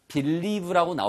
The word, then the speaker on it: believe: 예, 이건 리 액센트가 있기 때문에 원래 발음은 사전을 찾아보면 believe라고 나오지만 실제로 리를 강조하려면 앞에 있는 비 소리를 크게 하면 비 이렇게 입이 벌어지는 소리라면 그 뒤에 거를 하기가 생각보다 쉽지 않습니다. 그래서 부드럽게 believe, 마치 believe처럼 들리죠.